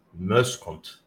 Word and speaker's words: Muyshondt: Well, oh, my, my last name is uh, from Oost Vlaanderen, Belgium, and it is pronounced Merskont.